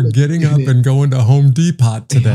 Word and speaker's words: Depot: Case in point, Home Depot is a silent T. But if you're deaf, it's Home Depot.